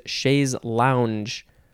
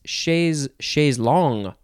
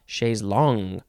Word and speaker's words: chaise longue: No, it's chaise longue, aha, chaise longue, because it is spelled L-O-N-G-U-E, it's like tongue with an L, and it's pronounced long, and tongue, you'd say chaise longue, it's similar, but it's not the same, chaise longue.